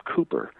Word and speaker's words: Kuyper: It's spelled differently as well, and I guess the Dutch actually pronounce his name Cooper.